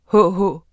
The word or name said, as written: hh